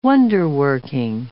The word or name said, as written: wonder-working